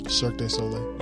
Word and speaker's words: Cirque du Soleil: It's like watching Circus du Soleil. – Cirque du Soleil. – Yeah, there it is.